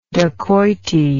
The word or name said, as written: dacoity